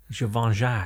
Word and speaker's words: Givenchy: Givenchy.